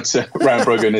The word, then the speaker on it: Rangbogen: Am I saying that right? Rangbogen prospect. – Yeah, there's a number of pronunciations depending on if you're local or not. But Rangbogen is what we go with.